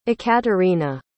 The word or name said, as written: Ekaterina